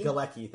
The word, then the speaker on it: Galecki: And I was definitely distracted by the fact that Griffin Newman, who plays Arthur, looks a lot like Johnny, I never know how to pronounce his last name, the guy from Galecki. – Galecki, thank you, from Big Bang Theory and Roseanne, who plays Leonard in Big Bang Theory.